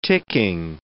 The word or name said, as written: ticking